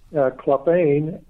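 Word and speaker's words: Klafehn: I believe if you go on the Trafford website and just, you know, punch in my name, Clefane, that you will find that, or punch in the title and you will get that also.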